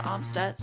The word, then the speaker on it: Amstutz: And I'm on all of those social media platforms at Sandra Amstutz. My last name is spelled A-M-S-T-U-T-Z.